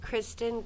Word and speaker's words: Kristen: D-A-R-C-I-E. Last name C-A-D-Y. – Darcy Katie. – Kristen, K-R-I-S-T-E-N, Meyercord, M-E-I-E-R-K-O-R-D.